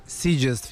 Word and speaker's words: Sitges: Pa da, bit će na, sad ja uvijek imam problem da izgovorim. – Mislim da je Sitges. – Sitges Film Festival.